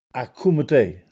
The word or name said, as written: Accumetè